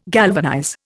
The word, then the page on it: galvanize: Indian